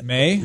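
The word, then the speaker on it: Mei: May rhymes with yay.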